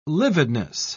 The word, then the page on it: lividness: British English